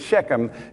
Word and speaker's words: Shechem: They seem to pronounce it Chem like that. Alfred, isn't that close? Pretty close, yeah. They have a guttural sound, Chem. It's Shechem, and important things are found there.